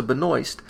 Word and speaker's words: Benoist: But after reading interviews with Melissa Benoist, which he claims I pronounced correctly, booyah!